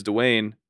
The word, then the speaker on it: Dwain: And when I tell them, I'm like, my middle name's Dwain, I'm like, try and spell it.